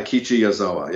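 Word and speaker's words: Ikichi Yazawa (矢沢永吉): Ikichi Yazawa? – Right, Ikichi Yazawa, yeah.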